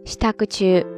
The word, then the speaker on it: 支度中: 还有一个单词，可能大家常常也会见到，叫做 “stakuchu”。